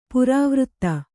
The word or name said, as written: ಪರಿವರ್ತ